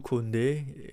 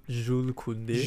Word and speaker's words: Koundé: Well, what is legit is, I think, Jules Koundé, if that's the right way to pronounce it in French. Sure. Jules Koundé.